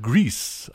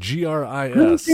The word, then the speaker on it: Gris: I'll do another independent film, another independent game called Grease. I believe is how it's pronounced. GRIS.